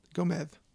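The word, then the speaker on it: Gómez: Gomez. Well, in Spain, you would not pronounce that a Z. You'd pronounce it a TH.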